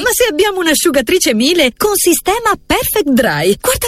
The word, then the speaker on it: Miele: E ricorda, si scrive Miele, ma si dice Miele.